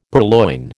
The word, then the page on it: purloin: American English